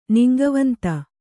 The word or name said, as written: ನಿಂಗವಂತ